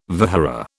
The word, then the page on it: vihara: British English